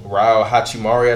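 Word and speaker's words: Rui Hachimura: Is it Rahu Makrama? – No, Rao Hachimari, I think that's how you pronounce it.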